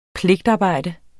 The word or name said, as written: pligtarbejde